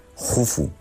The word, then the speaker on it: Jufu: se escribe Keops, pero se pronuncia Jufu, y casi nadie le llama Jufu, porque Herodoto no lo trae.